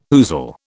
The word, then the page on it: ousel: British English